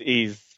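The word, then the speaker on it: Ys: But I quickly recognized it as Wise Origin, or Ease, or Eyes, or I don't know how you pronounce it. – It is Ease. – Ease, thank you.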